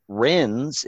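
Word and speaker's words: Rennes: And he figures out that Renz is actually – it's a French name. It's Ren. It's the S is silent.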